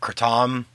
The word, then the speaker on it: Kratom: Yeah, so Kratom itself, and you'll hear it called Kratom, which is how we pronounce them. Also, you'll hear, like, Kratom. – Which I think is how you traditionally would say it.